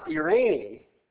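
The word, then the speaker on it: Irene (εἰρήνη): It's pronounced Irene, which is the same way you pronounce the word Irene in Spanish. Spelled the same, too, except there's an E in the front of it.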